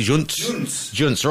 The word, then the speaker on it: Junts: Also looking at La Vanguardia, also has La Vanguardia and El Mundo also have these stories on Hunt. Am I pronouncing it correctly? Junts, right.